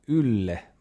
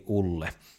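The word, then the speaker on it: Ülle: Ylle kirjoitetaan U:lla, missä on umlautit päällä, niin se U on ainon mukaan aika lailla niin kuin suomenkielinen Y-kirjain. Okei, eli Viron uuden presidentin nimi lausutaan Ylle Madise, jos vedetään vähän mutkia suoriksi. Ylle, ei Ulle.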